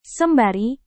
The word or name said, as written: sembari